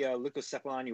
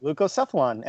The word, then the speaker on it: Leucocephalon: So I chose the Leucocephalon Ioannawiver. – Ooh, nice pronunciation. – Yeah, that's perfect. – Is that all right? – Yeah, it's perfect. Leucocephalon.